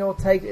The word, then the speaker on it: Ortega: I mean, Ortega, is it? That's how you pronounce it.